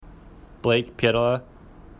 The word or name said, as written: Pietila